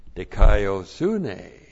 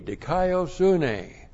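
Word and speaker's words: Dikaiosune (δικαιοσύνη): but this time because of dikaiosune. Dikaiosune, D-I-K-A-I-O-S-U-N, long E, dikaiosune.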